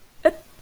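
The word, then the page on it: ít: Vietnamese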